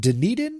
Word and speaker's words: Dunedin: Is it Dunedin? Is that how you say it? That's how I say it. Dunedin, Florida? – I don't know. I don't go to Florida. – Dunedin? – I think you're right.